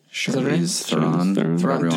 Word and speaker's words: Charlize Theron: Another thing is, did Charlize, I can never say her name, Charlize Theron? – Charlize Theron.